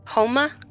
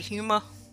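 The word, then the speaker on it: Houma: Okay, it's H-O-U-M-A. I checked the pronunciation on that one too, because I was not sure. – Let me tell you. – Because I'd have been like, Huma.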